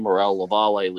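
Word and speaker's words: Morel-Lavallée: gave me the name Morel Lavallee lesions. That's how we pronounce it.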